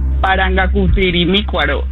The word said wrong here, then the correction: Parangaricutirimícuaro: Te sigue faltando una sílaba. Parangaricutirimícuaro.